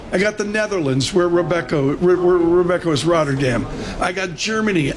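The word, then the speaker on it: Robeco: Depends if you're Dutch. It's Rebecca.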